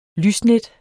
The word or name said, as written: lysnet